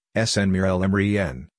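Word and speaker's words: Sanna Marin: With 196,039 views on Thursday, August 18, 2022, our article of the day is Sanamarin. Sanamarin, Finnish pronunciation, Sanamarin, born November 16, 1985, is a Finnish politician who has served as the Prime Minister of Finland since 2019.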